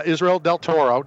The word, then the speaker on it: Israel Del Toro: He's sitting here right now, Israel Del Toro. Did I say that right? – Yeah, you said that right.